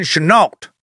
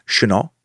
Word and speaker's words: Chennault: The French pronunciation is Chennault. But his family pronounced it Chennault because America, a nation where mushmouth is the norm.